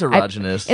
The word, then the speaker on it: erogenous: There's lots of erogenous zone. Is that how you pronounce that? I've literally never known. – It is erogenous. – It's erogenous? – You must have heard it.